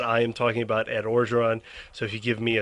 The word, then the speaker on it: Orgeron: Orgeron? Orgeron. I never say it correctly. You always have to correct me.